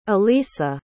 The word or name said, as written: alisa